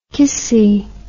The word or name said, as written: kissy